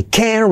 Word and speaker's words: can't: En afirmativo sería I can reach, en negativo, I can't reach, no pronuncio la T, pero acentúa la palabra.